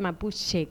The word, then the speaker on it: mapuche: se escribe mapuche y se pronuncia mapuche como una che suave.